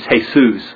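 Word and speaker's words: Jesús: In Spanish it's Jesus, but Jesus is just the Spanish pronunciation of the same alphabetical letters that are Jesus. It's just that Spanish doesn't have a J sound, so that's hey. So it's Jesus instead of Jesus.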